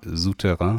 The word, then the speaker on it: Souterrain: Südter heißt das Südterrain? – Souterrain. Souterrain, ja, mhm.